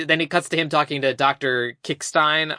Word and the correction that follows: Krickstein: I keep forgetting how the hell you pronounce that guy. – God damn it, Dr. Krickstein.